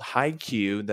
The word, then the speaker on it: Haikyuu: Who also got me on My Hero Academia, called Haikyuu. That's the way it's pronounced. I asked him, but it's spelled Haiku, and then IMDb wants it to have another U into exclamation points.